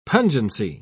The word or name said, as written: pungency